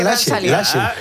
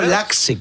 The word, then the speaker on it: Laxe: Pero mira, se pronuncia Lache. No Laxe, como dicen algunos.